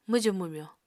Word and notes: mwjù-mwmyo: Apatani

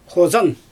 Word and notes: xozan: Xyzyl

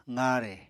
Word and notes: qaare: apatani

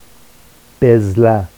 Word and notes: bezla: Vafsi

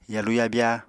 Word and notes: yalu-yabya: Apatani

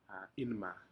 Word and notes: inma: Aneityum

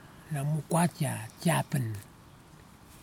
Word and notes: nɨmu kwatia tasiapen: kwamera

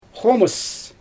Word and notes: xoomɯs: Xyzyl

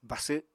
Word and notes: basw: Apatani

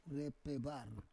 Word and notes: repevar: Roon

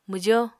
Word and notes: mwjo: Apatani

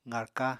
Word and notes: qarka: apatani

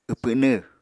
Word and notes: wpwnw: Apatani

